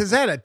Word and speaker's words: Tezeta: Tezeta, T-E-Z-E-T-A.